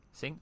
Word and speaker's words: Singh: S-I-N-G-H. Singh? Singa? Singh? Okay. – I would say Singh.